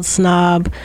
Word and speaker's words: PSNOB: It's called Snob, PSNOB. The P is silent.